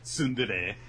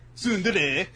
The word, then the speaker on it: tsundere: Tsundere. Tsundere.